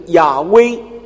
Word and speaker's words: 亚威: 那么这个发音呢，其实中文耶和华是比较接近，很多人认为，圣经学者认为那个亚威可能更接近原来那个字的发音。